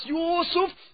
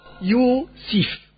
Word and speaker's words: Yusuf: Als Yusuf zu seinem Vater sagte, Yusuf oder Yusuf oder Yusif. Alle Aussprachen sind richtig. Yusuf, Yusuf, Yusif. Alle sind richtig.